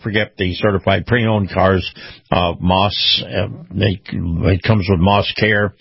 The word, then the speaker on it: Maus: Moss is spelled M-A-U-S, Family Automotive.